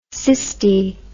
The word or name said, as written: cysti